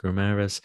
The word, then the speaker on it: Guimarães: And we'll go to the Brazilian, Bruno Guimarães. Is that how you pronounce it, I think? – Yes. – Guimarães. Yes.